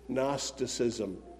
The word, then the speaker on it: Gnosticism: Boys and girls, Gnosticism is an odd word. It begins with the letter G, but the G is silent. G-N-O-S. Gnostic. Gnosticism.